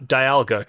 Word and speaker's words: Dialga: You got Dialga correct, although I think it's actually pronounced Dialga. – Is it? – Because it's tough because like clearly it's a pun. Clearly, it's a pun on dial, so Dialga would actually make more sense, but Dialga sounds better.